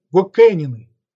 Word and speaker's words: Гокэнины: И эти вассалы назывались Гокэнины. Ударение на последнем слове.